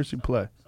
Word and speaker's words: Suplee: How did I say it? Suplee or Suplee? Suplee.